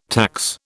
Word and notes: tax: British English

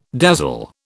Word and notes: dazzle: British English

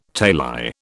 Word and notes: tali: British English

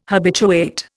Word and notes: habituate: Indian

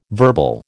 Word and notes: verbal: American English

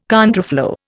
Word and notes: contraflow: Indian

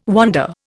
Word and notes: wander: Indian

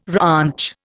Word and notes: ranch: Indian

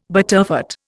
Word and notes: butterfat: Indian

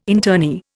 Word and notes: internee: Indian

